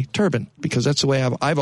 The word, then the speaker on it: turbine: Now, do you pronounce it turbine or turban? – Well, either way, depending, I guess, on who I'm talking to. I think it's turban, but I do too.